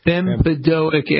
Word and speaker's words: bempedoic: Bempedoic? What is that stuff? Am I pronouncing it right? – Bempedoic acid.